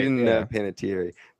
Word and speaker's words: Panettiere: Oh, Panettiere. – That's right. – Panettiere.